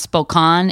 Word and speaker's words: Spokane: Apparently Spokane is actually pronounced Spokane in Washington.